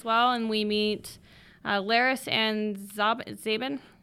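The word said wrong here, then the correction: Zhaban: Uh, they pronounce it Jaban.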